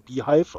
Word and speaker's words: beehiiv: Als ich dann zuerst gesucht habe, was Beehive, also erstmal habe ich geguckt, wie Beehive ausgesprochen wird, weil Beehive, diese Newsletter-Plattform, wird geschrieben BEEHIIV, also Beehive, aber es wird eben trotzdem Beehive ausgesprochen.